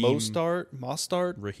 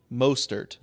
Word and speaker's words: Mostert: Raheem Mostert. – Mostert. – Yeah. – Mostert.